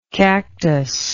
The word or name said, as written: cactoid